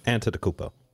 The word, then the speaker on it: Antetokounmpo: Antetokounmpo. – I can't pronounce Greek names. – That's how it's pronounced.